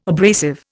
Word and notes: abrasive: Indian

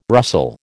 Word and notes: rustle: American English

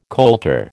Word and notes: colter: American English

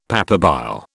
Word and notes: papabile: British English

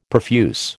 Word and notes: profuse: American English

